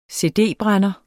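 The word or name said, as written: cd-brænder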